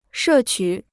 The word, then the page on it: 摄取: shè qǔ